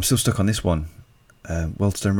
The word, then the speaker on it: Wealdstone: Wealdstone? – Yeah, I know who you mean.